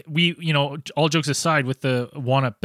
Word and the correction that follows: wampum: belt, is it the Wanup belt? How do I say it again? – The wampum.